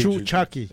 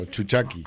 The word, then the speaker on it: Chuchaki: Y vamos a escuchar la Descarga con Chuchaki, ¿lo pronuncié bien? – Sí, Chuchaki. O sea, unido, Chuchaki.